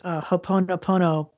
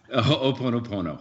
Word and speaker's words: Ho'oponopono: Ho'oponopono. Ho'oponopono. – Ho'oponopono. – Yeah, so ho'o is causative. So ho'o means kind of like to make. And pono means rightness or true condition of nature.